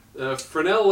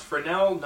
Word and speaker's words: Fresnel: Fresnel lens versus sailor soldiers. – And that's pronounced Fresnel, not Fresnel, however it's spelled.